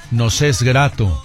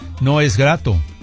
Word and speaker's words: nos es grato: Nos es grato. Fíjense qué importante, nos es grato. Hay que pronunciar la S porque podría decir no es grato.